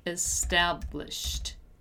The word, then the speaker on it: established: Once again, we have a simple past form of a regular verb, so it ends in ed, and once again, we don't pronounce the letter e. We say something like established, right? So established, established, established, right?